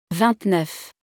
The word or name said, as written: 29